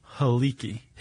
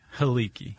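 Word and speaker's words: Helike: Hiliki? – Haliki. – Hiliki. – Haliki. – Haliki. – Yeah. – Okay. – Haliki. – Spelled he-like.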